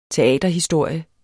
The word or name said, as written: teaterhistorie